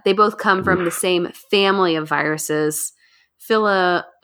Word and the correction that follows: Filoviridae: How do you say it, James? – Filoviridae, yeah. – Yeah, that's how you say it.